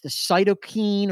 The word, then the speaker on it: cytokine: I think it's the cytokine or cytokine storm, however you say that.